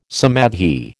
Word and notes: samadhi: American English

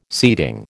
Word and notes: seating: American English

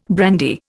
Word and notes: brandy: Indian

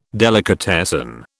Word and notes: delicatessen: British English

